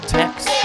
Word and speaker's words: Taix: That restaurant is called Tex. It's spelled T-A-I-X. It's a French restaurant.